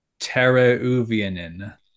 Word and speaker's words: Teräväinen: Teuvo Teräväinen, Teuvo, Terea with the two dots over it, V-A with the two dots over it, Inn.